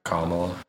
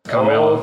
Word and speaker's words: Kamala: If I said her name right. – Kamala. – Kamala.